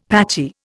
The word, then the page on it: patchy: Indian